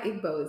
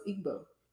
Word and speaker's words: Igbo: Okay, so it's not Igbo, it's Igbo. – Igbo. Igbo. Igbo. Um, okay. Um, that's how we say it as Igbos. We just say Igbo. The G right. is silent, but um, obviously, if it's there, it makes sense that people would say it like that.